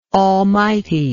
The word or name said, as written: almightiness